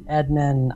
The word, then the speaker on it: Adnan: Did I pronounce that correctly? Everybody seems to pronounce it differently that tries to pronounce your name around me. – That's quite all right. I mean, Adnan, whichever way.